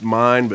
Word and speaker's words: Main: It's pronounced mine, but spelled Maine, and that didn't make a lick of sense to me.